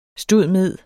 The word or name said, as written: stud.med.